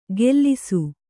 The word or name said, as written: ಗೆಲ್ಲಿಸು